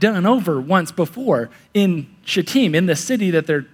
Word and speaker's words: Shittim: Then Joshua, there's our guy now, Joshua, son of Nun, secretly sent two spies from Shittim, is how you pronounce that. Yep, Shittim.